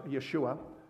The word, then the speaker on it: Yeshua: which in the Hebrew would be pronounced Yeshua, which is also the Hebrew name of Jesus, Jesus being the Greek version.